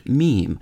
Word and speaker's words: meme: Having first considered, then rejected, mimeme, M-I-M-E-M-E, he wrote, "Mimeme comes from a suitable Greek root, but I want a monosyllable that sounds a bit like gene." The suitable Greek root was mim, M-I-M, meaning mime or mimic.